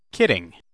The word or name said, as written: kidding